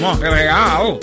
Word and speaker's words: Montreal: Andy Hill from Montreal. Yeah, that's the correct pronunciation, you know. Montreal. Not Montreal. That's for novices.